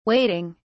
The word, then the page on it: wading: British English